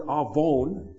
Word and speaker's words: avon: By looking in the book of Isaiah and surrounding prophets, how this word and where this word avon, it's actually pronounced or spelled in English, A-V-O-N, as Avon. Avon calling.